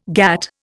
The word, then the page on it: GATT: Indian